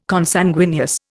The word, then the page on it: Consanguineous: Indian